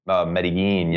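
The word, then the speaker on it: Medellín: Say it like a. – Give it to me again. How do you go? – Isn't it Medellin? – Medellin, yes, yeah.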